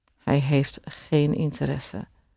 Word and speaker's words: interesse: And it's interesse. Interesse is spelled INTERESSE. So it ends in an E, and you always have to pronounce the ES at the end of a word.